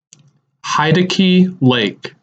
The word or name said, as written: Heidecke Lake